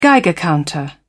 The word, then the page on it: geiger counter: British English